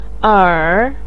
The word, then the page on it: er3: pinyin